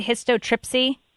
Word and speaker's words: histotripsy: Is that how you say it, histotripsy? – That is correct.